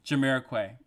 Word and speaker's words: Jamiroquai: Jamiroquai.